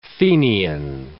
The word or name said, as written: Fenian